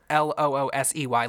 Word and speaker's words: Loosey: And it's spelled L-O-O-S-E-Y, like loose. Loose-y.